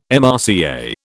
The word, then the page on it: MRCA: British English